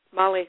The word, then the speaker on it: Mali: Yes, Mali, and West Africa.